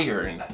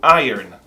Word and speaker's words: iron: E aí depois você assiste uma aula minha e você descobre que a pronúncia não é, nunca foi e nunca será Iron, nem Iron, ou Iron, porque a pronúncia de ferro é Iron. Iron, como se fosse eu ganho, I earn. Iron.